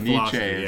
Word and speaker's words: Nietzsche: It's actually Nietzsche, how it's pronounced.